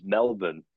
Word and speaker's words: Melbourne: The Australian pronunciation of Melbourne is Melbourne, and sometimes you can't help but say Melbourne because you're around that. And it's actually easier to say Melbourne than it is Melbourne.